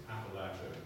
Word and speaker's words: Appalachia: When I was young, growing up on the East Coast, we only had the Appalachians. Okay? And the Appalachians are, it's Appalachia, it's not, it's the other way to pronounce it, Appalachia. It's Appalachia, like Appalachian State University.